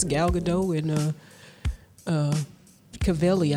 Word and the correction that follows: Cavill: Henry Cavill, whatever.